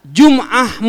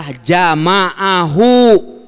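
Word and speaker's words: jama'ahu (جَمَعَهُ): Ya Bukhari itu ternyata namanya adalah Jum'ah Muhammad ibn Ismail Al-Bukhari, katanya gitu. Jum'ah. Padahal di situ maksudnya itu adalah jama'ahu Muhammad ibn Ismail Al-Bukhari. Tapi dia salah baca.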